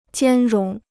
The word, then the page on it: 兼容: jiān róng